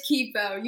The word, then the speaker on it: Kipo: It is Kippo. You got it right. Most people say Kippo.